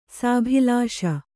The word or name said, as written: ಸಾಭಿಲಾಷ